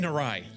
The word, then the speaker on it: awry: Yeah, things are really going awry right now. I mean, awry. Shoot. – You put the emphasis on the wrong syllable.